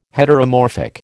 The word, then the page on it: Heteromorphic: American English